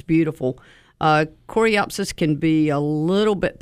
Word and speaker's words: Coreopsis: Then in front of that, maybe some coreopteris. Am I saying that correctly? – Yeah. – Coreopsis, yeah.